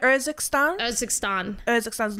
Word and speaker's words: Urzikstan: And the new big map, how do you pronounce it? – Urzikstan.